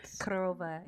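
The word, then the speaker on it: Korova (корова): Korova is how you say cow in Russian.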